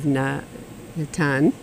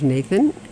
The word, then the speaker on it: Natan: So his name is Natan, Natan B'Shalom. Natan is Hebrew for gift from God. His name was Nathan, so I gave him the Sephardi pronunciation of Natan instead of Nathan.